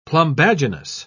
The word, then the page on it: plumbaginous: British English